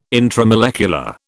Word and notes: intramolecular: British English

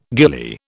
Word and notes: ghillie: British English

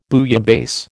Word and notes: bouillabaisse: American English

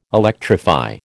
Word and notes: electrify: American English

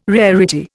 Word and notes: rarity: Indian

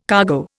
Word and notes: cargo: Indian

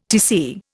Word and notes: Tc: Indian

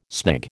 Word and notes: snig: American English

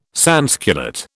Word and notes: sansculotte: British English

